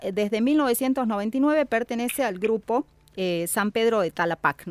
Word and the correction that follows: Tarapacá: No sé si lo estoy pronunciando bien. – Viña San Pedro de Tarapacá. – Tarapacá, bien.